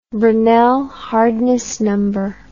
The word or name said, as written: Brinell hardness number